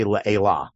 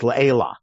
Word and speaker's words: le'ela: Israelis have a hard time unlearning that automatic principle, so they may say le'ela, but it's le'ela.